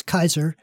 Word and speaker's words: Caesar: Just keep in mind that Julius Caesar, or more correctly, Julius Kaiser, would have wondered why we're mispronouncing so many words.